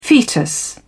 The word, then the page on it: fetus: British English